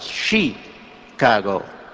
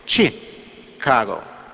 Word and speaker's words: Chicago: Once I was listening to him on television talking about the great city, our great city of Chicago, and he said, you don't pronounce it Chicago, it's chi, like chick, you know, or like chicken, Chicago.